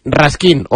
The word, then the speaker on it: Raskin: es Raskin, es como se pronuncia,